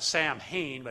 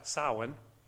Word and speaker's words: Samhain: Now, the most important religious day for the Druids was the celebration of, we would pronounce it Samhain, but it's actually Samhain, Lord of the Dead, basically Satanism.